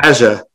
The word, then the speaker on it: Asjha: A-S-J-H-A. Asha? Asha? I don't know how to say her name. – Asha. – Asha.